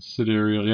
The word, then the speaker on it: Sidereal: Sidereal? – Sidereal, yes, what I would say.